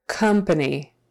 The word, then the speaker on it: company: Again, this O is pronounced like a short U. Company, company, company. And really, that second A is also pronounced like a short U when we're in um, conversation. Company.